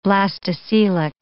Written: blastocoelic

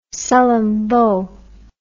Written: Sullom Voe

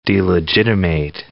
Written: delegitimate

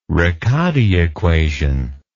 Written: riccati equation

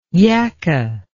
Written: yakka

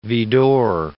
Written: Widor

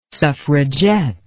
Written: suffragette

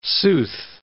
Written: sooth

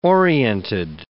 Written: oriented

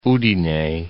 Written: Udine

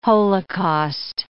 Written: holocaust

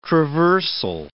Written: traversal

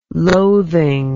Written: loathing

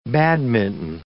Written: badminton

